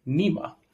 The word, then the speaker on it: NEAMA: I saw you guys laughing where you fixed my pronunciation and it is now pronounced NEAMA.